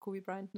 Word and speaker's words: Kobe Bryant: Zum Beispiel bei deinem Spieler sozusagen, das Trikot von Kobe Bryant und bei uns, da ah, ist doch Kobe Bryant, ne? – Ja.